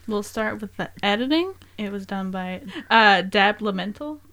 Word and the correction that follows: Leventhal: I think is how you say his name. – Dan! – I don't know why I can't say his name. – It's Dan Leventhal.